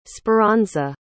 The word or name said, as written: speranza